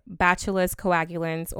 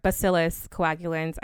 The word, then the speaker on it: Bacillus coagulans: Okay, so the first ingredient is Bacillus coagulans, or Bacillus coagulans. I've seen people pronounce it both ways.